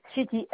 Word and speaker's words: Chetti: Alors, mon nom, c'est Madame Chetty. – Ça s'écrit? – Chetty, Chetti.